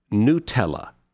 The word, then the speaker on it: Nutella: For example, I've always thought it was Nutella, that chocolate hazelnut stuff. It's actually pronounced Nutella.